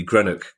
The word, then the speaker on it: Greenock: But I'm very firmly in the Greenock camp.